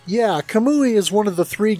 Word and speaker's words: Kamui: And in September, we have Kamui, which is that, forgive me if I pronounce, did I pronounce that right, Kamui? – Yeah, that's right.